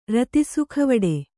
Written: ರತಿಸುಖವಡೆ

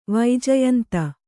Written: ವೈಜಯಂತಿ

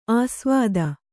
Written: ಆಸ್ವಾದ

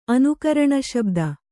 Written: ಅನುಕರಣಶಬ್ದ

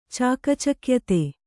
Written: ಚಾಕಚಕ್ಯತೆ

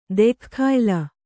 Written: ದೆಖ್ಖಾಯ್ಲ

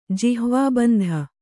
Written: ಜಿಹ್ವಾಬಂಧ

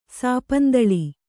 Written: ಸಾಪಂದಳಿ

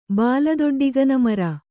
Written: ಬಾಲದೊಡ್ಡಿಗನಮರ

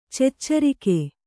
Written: ಚೆಚ್ಚರಿಗ

